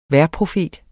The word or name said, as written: vejrprofet